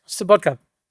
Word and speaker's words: Sobotka: Let me commend him, Mr. Wolfgang Sobotnik. Am I pronouncing that right? Yeah. Sobotka, who is an amazing person who brought parliamentarians from around the world for a conference, a terrific conference.